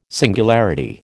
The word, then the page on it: singularity: American English